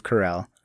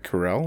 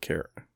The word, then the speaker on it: Carell: I think my next one, I'm going to pick Steve Carell. I love Steve Carell. – Is it Carell or is it Carrot? – It's Carell, Steve Carell.